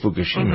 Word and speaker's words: Fukushima: Speaking of mayhem, the operator of the wrecked Fukushima, is that the way you pronounce it? The Japanese nuclear power plant was Fukushima, mm-hmm.